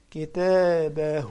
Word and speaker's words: كتابه: شوف لاحظ كلمة كتابه بعد الهاء المربوطة، في عندي واو صغيرة في بجوارها، يعني أنطق الضمة دي واو. كتابه.